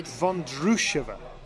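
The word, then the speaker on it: Vondroušová: Vondrousova is how we should be pronouncing this name, is what I'm told.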